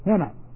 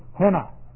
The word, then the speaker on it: hina: Hina. H-I-N-E, kind of a long E, H-I-N-A, henna.